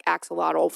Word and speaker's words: axolotl: So it's called the axolotl, and I'm just going to spell it because this is one of those things I had to listen to the pronunciation because I was like, I'm lost here. It's spelled A-X-O-L-O-T-L, axolotl.